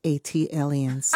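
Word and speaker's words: ATLiens: Tayari, just as Jill did for Philly, as an unofficial ambassador for Atlanta and Atlians. – That is pronounced Atlians.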